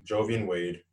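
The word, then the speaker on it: Joivan Wade: Jovian Wade or Jovian Wade? – Jovian Wade.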